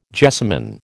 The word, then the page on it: jessamin: American English